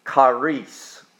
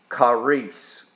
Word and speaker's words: charis: In the ancient Greek, it's pronounced charis, charis. But it's the word we get charity from, and it's C-H-A-R-I-S.